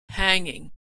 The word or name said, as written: hanging